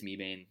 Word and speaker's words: Mebane: About the Chargers, how Brandon Mebane, is that how you pronounce his name? – Yeah. – It is Mebane.